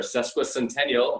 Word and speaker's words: sesquicentennial: It's our sesquicentennial. I'm so proud that I got that pronunciation correct, because it's a tough word to say.